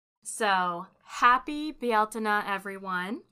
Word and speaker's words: Bealtaine: Bialtina is the Irish word for the month of May, and the word Irish pagans used for the celebration of May 1st. It is spelled Bealtaine, but others may be more familiar with its anglicized version, Beltane, spelled Beltane.